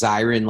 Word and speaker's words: Zirin: First, I got to tell you, the last name is pronounced Zirin, like a fire siren.